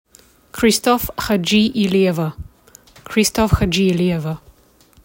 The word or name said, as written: Kalina Christoff Hadjiilieva